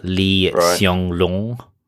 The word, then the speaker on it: Lee Hsien Loong: Singapore's Prime Minister, Lee Hsien Loong.